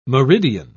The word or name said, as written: meridian